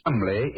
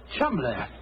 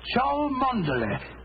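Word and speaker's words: Cholmondeley: Being an American, he had no reason to know that the name Chumley is in no way pronounced the way it is spelled. – Oh, I never thought of that. Chumley. That name spelt C-H-O-L, Chol, M-O-N, Mon, D-E-Dur, L-E-Y.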